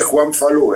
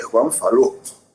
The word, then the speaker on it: Juan Falú: Tú no eres Juan Falú, eres Juan Falú.